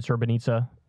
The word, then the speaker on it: Srebrenica: It's, I said Srebrenica. It is that. It's just spelled weird. S-R-E-B-R-E-N-I-C-A. – Yeah, it's spelled like Srebrenica.